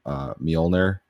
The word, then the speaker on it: Mjolnir: So the Inferno missiles do thermal, Mjolnir does EM, the J is silent.